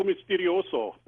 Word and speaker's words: Misterioso: Actually, if you want to pronounce it like Spanish, it's Radio Misterioso. But yeah, Radio Misterioso, most people call it.